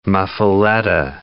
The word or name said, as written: muffuletta